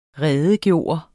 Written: redegøre

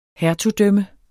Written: hertugdømme

